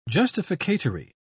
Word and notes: justificatory: British English

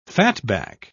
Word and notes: fatback: British English

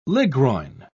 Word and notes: ligroin: British English

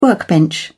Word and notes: workbench: British English

